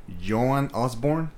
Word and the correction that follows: Joan Osborne: Joan Osborne? – Yeah.